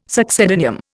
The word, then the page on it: succedaneum: Indian